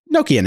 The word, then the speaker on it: Nokia: They're just going to be Nokia now. Or Nokia, as you're supposed to pronounce it.